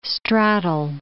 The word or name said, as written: straddle